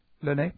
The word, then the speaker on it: Liene: It's L-I-E-N-E. How does she pronounce that? Spanish lady, L-I-E-N-E. Lene, just Lene, that's what I thought.